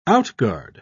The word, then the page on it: outguard: British English